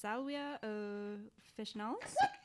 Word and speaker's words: Salvia officinalis: Let's see. Salvia officinalis. They pronounce their V's like W's.